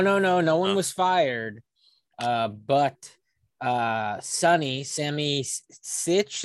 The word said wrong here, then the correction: Tammy Sitch: is that how you say her name? – Tammy Sitch. – Yeah, I said Sammy too.